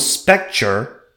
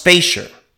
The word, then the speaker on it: Spejcher: Because I remember, I thought it was Spejcher, but it's actually pronounced Spejcher.